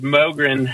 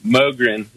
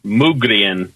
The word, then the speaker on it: Mogren: It's Mogren. M-O-G-R-E-N. Mogren. It's a Swedish name. I guess the Swedes pronounce it Mugrien, but I'm one of those Americans, so we mispronounce everything. We call it Mogren.